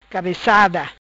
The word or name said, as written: Cabecada